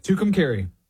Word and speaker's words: Tucumcari: Tucumcari.